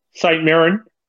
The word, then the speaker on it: St Mirren: So the Daily Record reports Keanu Backus has signed a two-year pre-contract deal to move this summer to Scottish Premier League club St Mirren. Did I say that right? – St Mirren.